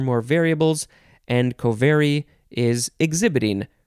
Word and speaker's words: covary: I think you would pronounce it covary. You have to emphasize the ver, covary.